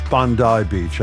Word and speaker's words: Bondi: It's Bondi Beach.